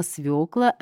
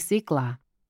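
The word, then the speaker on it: свёкла: Буква «ё» у нас всегда стоит под ударением, поэтому верная форма «свекла», а никак не «свекла».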